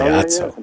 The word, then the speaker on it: Otso: How do you say this? – I'd say Otso. – Yeah. O-T-S-O.